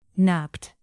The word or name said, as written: napped